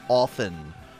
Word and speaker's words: often: Notice my correct pronunciation of often. The T is silent, people.